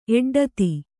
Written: ಎಡ್ಡತಿ

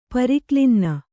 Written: ಪರಿಕ್ಲಿನ್ನ

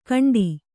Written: ಘಂಟಾ

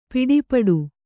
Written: ಪಡುಪಾಟು

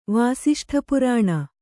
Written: ವಾಸಿಷ್ಠಪುರಾಣ

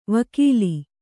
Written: ವಕೀಲಿ

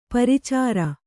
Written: ಪ್ರಚರ

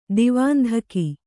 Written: ದಿವಾಂಧಕಿ

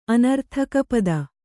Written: ಅನರ್ಥಕಪದ

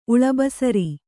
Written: ಉಳಬಸರಿ

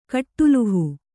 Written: ಕಟ್ಟುಲುಹು

